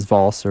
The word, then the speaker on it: valse: I'm not sure if it's valse or vals.